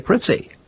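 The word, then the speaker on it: Prizzi: I should think that anywhere outside of Italy, you would say Preetzi. And Preetzi is the Italian pronunciation.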